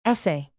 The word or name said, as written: essay